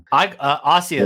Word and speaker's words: Asia: I call it Asia Argento as well. So am I getting that wrong? – Ossio is how I pronounce it. – Okay. – Yes. – Interesting. I've always said Asia, but I'm no doubt the wrong one.